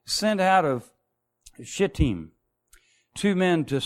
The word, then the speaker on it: Shittim: And that's the location, and it's spelled Shittim, but it's pronounced Shittim, Shittim.